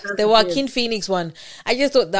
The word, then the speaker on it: Joaquin: Joaquin, like the J's. – Okay. – The J is silent.